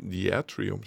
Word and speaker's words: Diatrium: Diatrium spricht man es. Englisch sprechen wir es besser nicht aus.